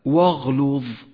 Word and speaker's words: واغلظ: ولاحظ الواو المرققة جاءت بعدها همزة الوصل التي لا تلفظ والتي لونت باللون الرمادي إشارة إلى سقوطها من النطق بعد ذلك حرف الغين المفخم ثم حرف اللام المرقق ثم حرف الضاء المفخم فقرأ هكذا واغلظ واغلظ